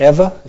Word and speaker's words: Eva: By the way, I would pronounce it Eva, if you read it in Hebrew.